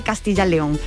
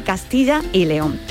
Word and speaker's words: Castilla y León: Y bueno, bueno, ahí están estas cuestiones identitarias, pero dicho que ha esto de que eh, castellano-leones se escribe todo junto y no se dice Castilla-León, se dice Castilla y León.